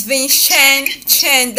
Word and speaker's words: Vincenzo: It's Vincenzo. That C-E-N is pronounced as Chen.